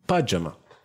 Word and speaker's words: pajama: Donc euh, Pyjama. – Pajama. Pajama. C'est pajama.